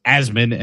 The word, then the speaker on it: Assman: But the S is a Z. And the I in man is, or the M in man, I should say, is actually an I. So it's Assman.